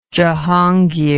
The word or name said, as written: jahangir